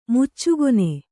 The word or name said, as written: ಮುಚ್ಚುಗೊನೆ